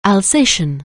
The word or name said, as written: Alsatian